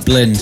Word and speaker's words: BLND: Just to let you know, if you're wondering, you spell it B-L-N-D and you pronounce it Blind.